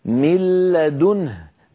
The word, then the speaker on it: من لدنه: أما مثال النون كما نرى فمثاله من قوله تعالى من لدنه كان النطق قبل الإدغام من لا، نون ساكنة بعدها لام، بعد أن أدغمنا النون في اللام صار النطق بلام مشددة.